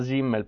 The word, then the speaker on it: Simmel: Si scrive Simmel. Però in tedesco si pronuncia Simmel perché la S normale è dolce.